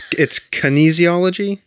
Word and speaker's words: kinesiology: Okay, so it's kinesiology.